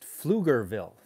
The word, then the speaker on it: Pflugerville: I read about a story about a young man, pronounced Pflugerville. Thank you. Who would have guessed the P was silent?